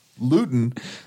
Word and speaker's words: Luton: A joke if you don't pronounce Luton, because that rhymes with gluten.